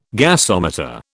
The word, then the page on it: gasometer: British English